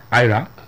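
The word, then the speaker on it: Ayra: It's not Era, right? Era Star, is that how you pronounce it? Ira.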